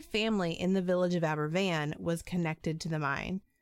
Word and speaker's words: Aberfan: So Aberfan, Wales, which is spelled A-B-E-R-F-A-N, but pronounced as if that F is a V, is a Welsh coal town in the Taff Valley, and it basically only existed because of coal mining.